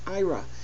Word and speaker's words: Aira: A program called IRA, or a company called IRA, A-I-R-A, pronounced IRA.